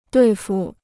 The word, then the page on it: 对付: duì fu